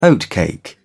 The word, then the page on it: oatcake: British English